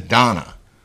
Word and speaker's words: Dana: No, I know it's spelled Dana, but she told me to my face, she pronounces, her name is Donna.